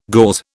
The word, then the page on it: gauze: British English